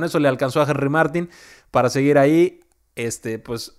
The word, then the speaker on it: Martín: Henry Martín, así con acento en la I, porque hay algunos que dicen, ah, pues conviene más si le decimos Martín porque es Henry. No, no, no, es Martín.